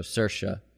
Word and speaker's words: Saoirse: So the way to remember to say Saoirse Ronan is it rhymes with inertia. So Saoirse.